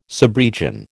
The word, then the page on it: subregion: American English